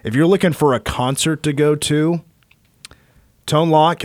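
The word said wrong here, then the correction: Tone Lōc: am I saying that right? Tone Loke. Tone Loke. Oh, Lord. Excuse me. Excuse me. Sir. I've always said Tone Lock for some reason.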